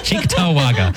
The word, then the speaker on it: Cheektowaga: and now Henry from Cheektowaga. – Cheektowaga. Cheektowaga. That's how us Western New Yorkers pronounce it.